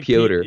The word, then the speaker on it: Piotr: It's like Piotr. It's Peter without the second E, so it's kind of a hard one to pronounce.